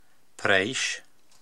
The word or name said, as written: Preysh